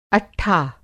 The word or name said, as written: अट्ठा